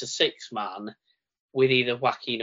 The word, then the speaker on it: Joaquin: But we've got Rey Mysterio, Santos Escobar, and either Cruz del Toro or, how am I pronouncing this one, Joaquin Wild. Or like Joaquin Phoenix, there we go.